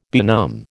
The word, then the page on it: benumb: American English